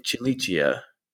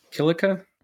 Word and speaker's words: Cilicia: Yeah, you know, when I teach my Latin kids, I teach them with the ecclesiastical pronunciation. And we would say, for the province, we would say Cilicia. And for the device, we would say Cilice, I guess. – Okay. – I think you learned old, like classical Latin pronunciation, didn't you? – Yeah. – And so you're saying Cilica?